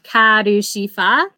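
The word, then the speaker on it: Karushifa (カルシファー): Also, fun fact, in Japanese, Calcifer's name is the same, but because of the way the Japanese language works, it's pronounced like Karushifa is how they would phonetically spell Calcifer.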